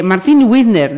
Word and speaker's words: Martín Wizner: Eh, Martín Widner, decía yo, no sé si lo he pronunciado bien o no.